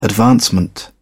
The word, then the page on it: advancement: British English